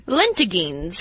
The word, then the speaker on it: lentigines: Lentigines.